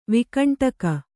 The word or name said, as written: ವೈಕುಂಠಿಕೆ